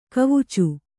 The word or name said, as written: ಕವಿಚು